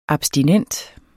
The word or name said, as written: abstinent